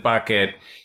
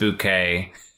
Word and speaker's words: Bucket: You know, like he pronounces it Bucket. She's decided it's pronounced Bouquet.